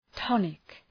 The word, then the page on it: tonic: ‘tɒnık